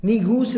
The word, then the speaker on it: Negus: Negus is the the most appropriate pronunciation of it. It's the proper pronunciation of it, and that was Negus, spelled N-E-G-U-S, Negus An-Najashi in Arabic, or even in that African dialect, it was called An-Najashi, Najashi.